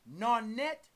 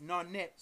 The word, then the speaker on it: Naunet: Nun is spelled N-U-N, sacred divine masculine. Nonet, N-A-U-N-E-T. That's Nonet, sacred divine feminine. Now, the second pair, Ha, H-U-H.